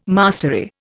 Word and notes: mastery: Indian

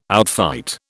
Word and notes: outfight: British English

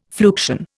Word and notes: fluxion: Indian

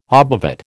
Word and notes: obovate: American English

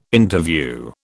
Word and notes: Interview: British English

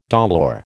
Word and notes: dolour: American English

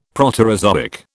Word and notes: Proterozoic: British English

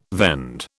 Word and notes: vend: British English

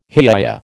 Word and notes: hiya: American English

